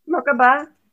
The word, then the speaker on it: Merkabah: Our combined Merkabah, how do you pronounce that? – Merkabah. That's good. – Merkabah, is that good? Okay. – Yeah, that's good. That's how I say it.